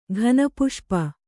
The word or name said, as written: ಘನಪುಷ್ಪ